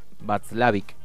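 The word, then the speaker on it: Watzlawick: De Paul Václavic, o algo así, si es que lo estoy pronunciando bien, para que... Václavic, así se pronuncia.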